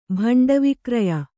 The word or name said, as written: ಭಂಡವಿಕ್ರಯ